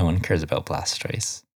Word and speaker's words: Blastoise: The Blastios? – I'm sorry. Did you just call Blastoise Blastios? – I wasn't sure.